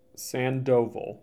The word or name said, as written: Sandoval